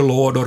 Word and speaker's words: lådor: Alf får exemplifiera: jag ska vara pärna och mycket lådor på jul. Lådor med kort å.